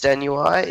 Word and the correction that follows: Stuani: Is that Stuani, how we... I think, yeah. – Stuani, yeah, sorry.